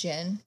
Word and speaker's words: djinn: Is it just djinn? The D is silent. – Yeah. That's a genie.